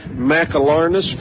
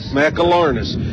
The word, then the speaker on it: McAlarnis: Shortstop Alyssa Dixon bats first, catcher Lauren Morris, first baseman Kylie McAlarnis, four syllables there, help me keep that, McAlarnis,